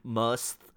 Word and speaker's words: musth: M-U-S-T-H. I think I'm saying that right.